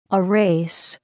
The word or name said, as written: erase